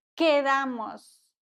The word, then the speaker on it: quedamos: ¿Dónde quedamos? QU wird wie ein K ausgesprochen.